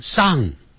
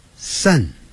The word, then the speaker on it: sun: 太阳叫 sun，哎，不要念成上，不是念上啊。你看，有点像一二三的三， sun。